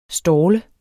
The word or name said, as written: stalle